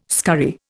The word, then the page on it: scurry: Indian